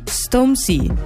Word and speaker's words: Stormzy: Sto parlando di Stormzy, giuro che si dice così, ho cercato la pronuncia su Google 3 secondi fa.